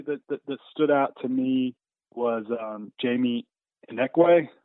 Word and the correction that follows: Ichinike: Am I saying that right? – I think it's Ichinike. So it's that's Ichinike. A tough one.